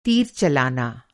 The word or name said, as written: तीर